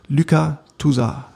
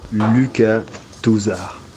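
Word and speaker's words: Lucas Tousart: Daraufhin habe ich jetzt mal einen Freund aus Frankreich angeschrieben, er möge mir doch bitte einmal die korrekte Aussprache ähm, zuliefern. Und er hat natürlich prompt eine Pfeil geschickt und wir legen los mit, hoffentlich sage ich es jetzt richtig, Alex. Oh Gott, Luca Tusa, hört selbst.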